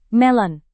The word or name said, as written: melon